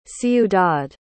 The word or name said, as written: ciudad